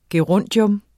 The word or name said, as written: gerundium